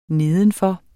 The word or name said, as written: nedenfor